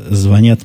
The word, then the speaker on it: звонят: которые звонят мне, вы видите, как я сегодня стараюсь и слове «звонят» ставлю в правильном месте ударение, ожидаю всячески ваших похвал.